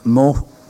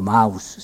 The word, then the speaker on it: Mauss: Cioè, parliamo delle procedure o dei riti sacrificali che per esempio il grande antropologo Marcel Mauss, o Mauss, se si pronuncia in tedesco,